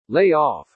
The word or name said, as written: lay off